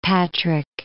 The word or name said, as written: Patrick